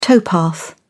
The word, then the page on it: towpath: British English